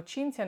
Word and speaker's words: Cinzia: Quindi nelle riunioni, nelle video call, quando dicevano Kinja, tu vuoi dire qualcosa? Sinja! E questo mi indisponeva, perché comunque Cinzia non era una cosa difficile da pronunciare e loro erano perfettamente in grado.